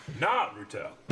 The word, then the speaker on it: Naruto: Eric Slap Rick for mispronouncing Naruto. – Naruto.